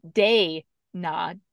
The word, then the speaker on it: Dayna: um, watching with captions, I found out that Dana's name is spelled D A Y N A. – Yeah. Oh, yeah. I guess you didn't know that. No. yeah, that's that's uh, it's day nah day, yeah.